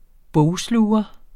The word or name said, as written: bogsluger